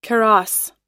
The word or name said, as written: kaross